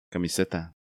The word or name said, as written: Camiseta